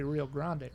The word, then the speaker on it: Rio Grande: Rio Grande is a very anglicized way of saying it. So it's got to be Rio Grande, right? – I say Rio Grande, but you know.